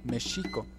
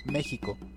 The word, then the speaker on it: México: Los aztecas lo pronunciaban mexico, los españoles lo escribían México, ya que no existía la pronunciación de la J. Cuando cambió la grafía de la X a la J, se le empezó a llamar México con J, pero se siguió escribiendo México con X, lo cual es válido, pues la Real Academia de la Lengua permite excepciones para nombres propios.